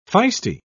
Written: feisty

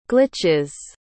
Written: glitches

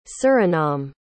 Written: Suriname

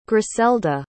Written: gricelda

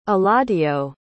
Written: eladio

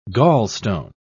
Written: gallstone

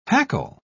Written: hackle

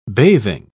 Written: bathing

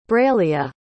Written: braleah

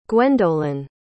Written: guendolen

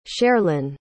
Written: cherlin

